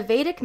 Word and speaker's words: Vedic: She is a Vedic meditator.